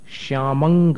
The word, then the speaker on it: श्यामं: अब इसके आगे वाला काव्य है, श्यामंग गभीर, अंग अंग जैसा उच्चारण है, उमंग जैसा, श्यामंग गभीर, अनुस्वार का उच्चारण बता रहे हम, श्यामंग गभीर गिरमुज्ज्वल।